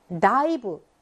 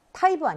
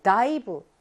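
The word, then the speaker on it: 다이브 (だいぶ): 다이브. 타이브 아니고 다이브.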